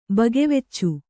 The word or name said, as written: ಬಗೆವೆಚ್ಚು